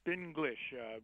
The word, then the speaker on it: Spinglish: Spinglish? – Yep, Spinglish. We put a hyphen in there, so it wouldn't sound like Spanglish, but it's really, as you had said, it's treating spin as a particular subset of the English language.